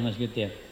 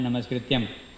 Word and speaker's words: Namaskritya: So some devotee may pronounce this Narayanam Namaskrityam. Namaskrityam. Krityam is to be done. Krityam means to kartabya. Yeah, is mentioned here. Kartabya means to be done, the duty. So Namaskrityam is not the right word in that sense.